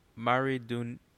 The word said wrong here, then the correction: Maridueña: Maradona. I don't know how to pronounce his last name. It's Hispanic. – Zolo Maridueña.